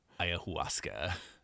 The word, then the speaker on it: ayahuasca: It's actually pumpkin-spiced ayahuasca. That's how you say it.